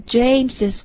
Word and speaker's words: James': 比如说 Whose is this car，这是谁的车，那么我们要说这是 James 的车，我们既可以说 It's James' car，也可以说 It's James' car。如果你加了用一撇 s 的形式的话呢，这个读音的时候要注意一下，应该把这个后面的 s 读出来， It's James' car。